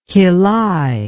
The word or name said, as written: quillai